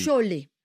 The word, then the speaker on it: cholet: On va les prononcer mieux encore. – Allez-y. – Cholet. – Cholet. – Cholet. – Oh, bah, la française, en fait.